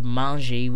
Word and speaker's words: mangent: And here is the step ER off to get the stem of the verb, which is M-A-N-G. And you would throw in M-A-N-G-E-N-T as the correct translation, or conjugation, sorry. Thus, it would come out as il mange or elle mange for they eat. But, like I said, the EN is silent.